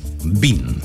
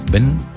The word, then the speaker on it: Ben: Bueno, claramente se pronuncia Bean, ¿cierto? Ben en español. Nosotros por eso la presentamos de ambas formas. Ben o Bean.